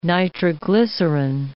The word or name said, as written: nitroglycerine